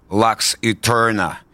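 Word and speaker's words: Lux Æterna: И вот спустя шесть лет молчания металюги представили новый сингл, который они произносят так «Lux Eterna».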